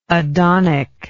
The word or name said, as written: adonic